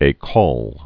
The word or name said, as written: haute ecole